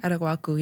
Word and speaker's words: Araguaco: Did I say it right? – Araguaco, yeah. It's okay. Like guac.